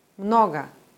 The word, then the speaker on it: Много: Im Wort Mnoga zum Beispiel ist das erste O betont und wird deswegen deutlich als O ausgesprochen. Das O in der Silbe danach wird zum AI-Laut reduziert. Много.